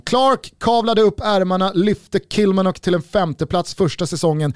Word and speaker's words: Kilmarnock: Är det, är det Kilmarnock eller Kilmarnock? Kilmarnock. Jag gillade den första bäst. – Kilmanock. – Kilmanock. – Ja, vi slår fast Kilmarnock.